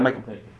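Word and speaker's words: Michal: Is that Michelle? Michael? Is that a girl's name? Yeah, Michael. Michael.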